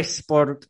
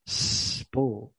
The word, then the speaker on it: sport: Es que siempre me dicen sport, And I'm like, esa mini e fuera. It's not sport. it's sport. Today I want to talk about sport.